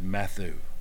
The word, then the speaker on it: Matthew: Or as Dr. R.G. Lee said, Matthew. That's a southern pronunciation.